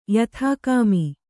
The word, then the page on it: ಯಥಾಕಾಮಿ: yathākāmi